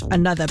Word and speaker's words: Anotr: Another, it's the one that's spelled A-N-O-T-R, right? And it's pronounced Another.